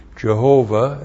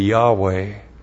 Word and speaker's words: Yahweh: If you pronounced it, you would be Jehovah. That's anglicizing it. In the Hebrew, it would be Yahweh.